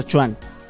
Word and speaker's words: quan: O sea, porque vos ves que le ponen Quan, pero se pronuncia Chuan.